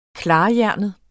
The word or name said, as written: KLARHJERNET